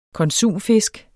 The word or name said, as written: konsumfisk